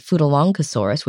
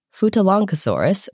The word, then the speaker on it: Futalognkosaurus: So we're just kind of pronouncing it like it's long, even though it's L-O-G-N. – Anyway, Futalongosaurus was a titanosaur sauropod that lived in the late Cretaceous in what is now Argentina, in the Portezuelo Formation.